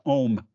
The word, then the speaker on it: Ogham: It's just pronounced Ohm, by the way. – Yeah, I know. – It looks like Ogham, but it's just pronounced Ohm.